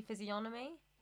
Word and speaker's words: physiognomy: Maybe it's a silent G. Physiognomy. – Yeah. I just said that. Physiognomy. You meant sign sheet, a physiognomy? – I was meant to be agreeing with you. Yeah, well, anyway, I think it's a silent G. Like, physiognomy.